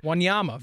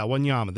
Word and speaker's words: Wanyama: Is that how you say his name? He's been with Tottenham for years, midfielder, 97 appearances for them over the last few years. Before that, Wanyama, Victor Wanyama. – Yeah, Wanyama. There you go. Thank you. I always put the emphasis on the wrong syllable.